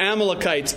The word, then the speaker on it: Amalekite: Well, it could be Amalekites, it can be Amalekites. All right, I'll probably use both just so everybody can be happy.